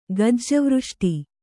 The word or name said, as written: ಗಜ್ಜವೃಷ್ಟಿ